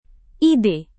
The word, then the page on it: ide: Indonesian